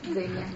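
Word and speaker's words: Zegna: Зенья, да, произносится. Ну это итальянские правила чтения, да, тоже известны наши марки.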